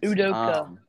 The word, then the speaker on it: Udoka: Udoka.